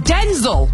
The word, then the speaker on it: Denzel: He says it's pronounced Denzel, but even he calls himself Denzel.